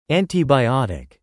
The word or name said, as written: antibiotic